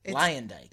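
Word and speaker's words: Luyendyk: So this season we have Ari Lyandike, which I didn't know is how you pronounce it. – It's spelled and pronounced exactly like you would never guess. – Exactly. – Lyandike.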